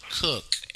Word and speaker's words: Coke: His name is spelled Coke, C-O-K-E, but the English pronounced it Cook, evidently.